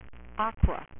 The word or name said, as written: aquaman